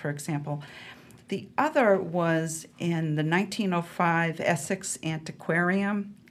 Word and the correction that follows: Antiquarian: Am I saying that right? – Antiquarian, yeah.